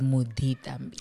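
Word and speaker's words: Muditambi: But the correct pronunciation of it is Mudi Thambi.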